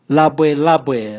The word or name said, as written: labwelabwe